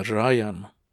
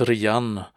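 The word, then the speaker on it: Rian (РЯН): Men eh, egentligen ska det uttalas Rian för att det, det är en förkortning av raketna jadrnaje napadenie, vilket är raketangrepp på ryska.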